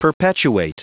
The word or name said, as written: perpetuate